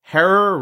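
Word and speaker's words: Herer: If you're thinking we must be talking with the man Jack Herrer, rhymes with terror, the Hemperer himself, I've got some unfortunate news for you, which is that he passed away quite a few years ago.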